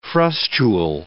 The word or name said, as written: frustule